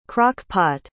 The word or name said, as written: crock-pot